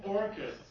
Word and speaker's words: Orkut: Or think about Orkut, or no, actually no, it's pronounced Orkut. I learned that not too long ago.